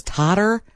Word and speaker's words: totter: if it was totter, which it is, T-O-T-T-E-R, or T-A-W. I'm like, it's teeter totter, but we say teeter totter. – Oh, I say totter. T-O-T-T-O-E-R. – It doesn't rhyme with water.